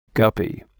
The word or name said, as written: guppy